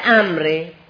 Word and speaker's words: عمرو: تجنب صدیقا مثل ما وحذر الذی، دوری کن از رفیقی هم که مثل عمرو. دیگه امر حالا چجور آدمیه که از این دوری کنیم؟ یعنی کلمه امر، لفظ امر که با چی نوشته میشه؟ با واف. یک وافی داره که نه تلفظ میشود و نه به درد میخوره، یک چیز زائدی داره.